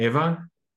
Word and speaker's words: Eva: I'm here with, I hope I say this right, Eva. Is it Eva? – Uh-huh.